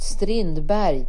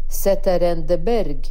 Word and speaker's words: Strindberg: August Strindberg, eller Zetterendeberg som han uttalade.